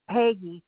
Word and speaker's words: Peggy: Did you say your name was Betty or Petty? Peggy. – Peggy, P-E-G-G-Y, Stewart.